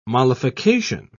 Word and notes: mollification: British English

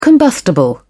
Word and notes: combustible: British English

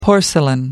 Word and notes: porcelain: American English

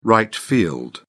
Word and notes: right field: British English

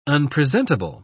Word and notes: unpresentable: British English

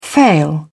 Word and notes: fail: British English

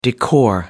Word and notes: decor: American English

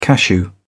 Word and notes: cashew: British English

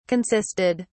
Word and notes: consisted: British English

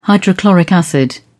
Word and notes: hydrochloric acid: British English

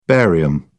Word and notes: barium: British English

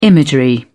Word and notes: imagery: British English

